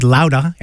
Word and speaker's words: Loudah: Really happy to start that set off with Sal Baglioni Amplifier Heads, brand new CD called Louda, L-O-U-D-A-H, pronounced Bostonian.